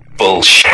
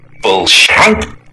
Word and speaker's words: Bullshank: Bullshit. – Can you spell that for me? – Yeah. You ready? – Yes. – B-U-L-L-S-H-A-N-K. Bullshit.